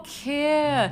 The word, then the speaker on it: kir: Like the car? – No. Like, spelled like K-I-R. – Kia.